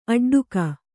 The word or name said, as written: ಅಟ್ಟಾಟಿಕೆ